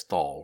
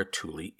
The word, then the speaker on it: Thule: Now, as a side note, Thule is spelled T-H-U-L-E, which I would normally say as Thal or something like that. But the interweb says to say Thule, so that's what I'm going with.